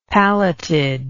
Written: palleted

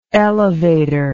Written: elevatory

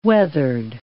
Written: weathered

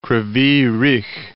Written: Kryvyy Rih

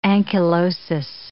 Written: ankylosis

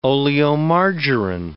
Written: oleomargarine

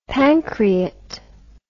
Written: pancreat

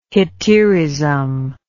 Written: hetaerism

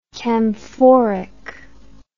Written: camphoric

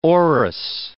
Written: orris

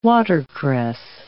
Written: watercress

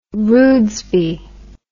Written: rudesby

